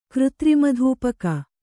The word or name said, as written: ಕೃತ್ರಿಮಧೂಪಕ